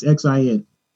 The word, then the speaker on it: Xin: How do you pronounce Xin? Is it XI? It's XIN.